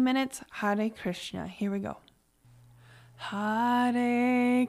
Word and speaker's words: Hare: And it's Hare, which is H-A-R-E, and I pronounce it with a soft R.